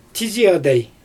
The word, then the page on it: tizi adaj: Xyzyl